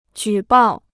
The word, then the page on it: 举报: jǔ bào